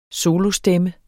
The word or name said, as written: solostemme